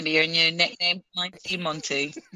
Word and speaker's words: Mante: And he spells Monty, M-A-N-T-E. But it is Monty pronunciation.